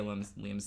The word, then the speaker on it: Liam: Liam Sr. I almost said Lim Sr. Almost mispronounced my own God-given name.